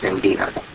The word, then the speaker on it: Ngina: Ingina, N-G-I-N-A, pronounced Ingina.